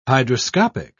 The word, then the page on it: hydroscopic: British English